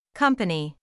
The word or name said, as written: company